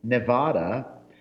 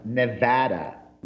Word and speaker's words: Nevada: I also learned repeatedly that even if the majority of the people in this country call the state Nevada, locals get very upset if you call it that. It is properly pronounced Nevada.